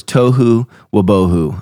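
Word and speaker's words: tohu wa-bohu: Yeah, it's because I'm saying it wrong. I apologize, John. The word is Tohu Wabohu. I don't know why I'm saying Tomahov, but I've been saying that for a while. It's Tohu Wabohu, and it is found in Genesis 1:2.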